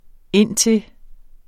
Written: indtil

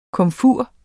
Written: komfur

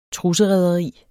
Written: trusserederi

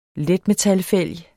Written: letmetal

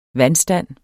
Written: VANDSTAND

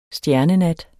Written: stjernenat

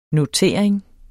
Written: notering

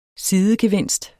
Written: sidegevinst

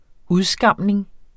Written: udskamning

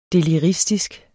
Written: deliristisk

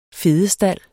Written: fedestald

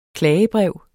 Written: klagebrev

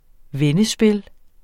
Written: vendespil

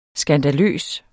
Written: SKANDALER

